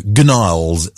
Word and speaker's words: Gnarls: Silent G, you know. Gnarls, it would otherwise be pronounced.